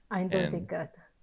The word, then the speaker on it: Eindeutigkeit: One first topic is, I don't know if I'm going to pronounce it properly, but Eindeutigkeit. – Eindeutigkeit.